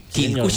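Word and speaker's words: Quilcué: Quilcue. Sí, Quilcue, señor, por fin lo dije bien.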